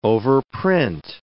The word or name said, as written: overprint